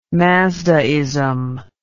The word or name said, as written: mazdaism